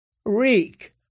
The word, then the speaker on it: Reek: "Reek, I'm Reek. It rhymes with squeak."